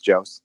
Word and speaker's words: Jost: It's spelled Jose. So a lot of people were like, Natalie Jose. And she's like, no, it's Jost.